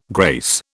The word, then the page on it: grace: British English